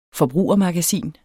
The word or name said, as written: forbrugermagasin